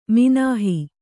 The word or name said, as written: ಮಿನಾಹಿ